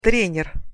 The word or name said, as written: trener